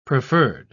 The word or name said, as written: preferred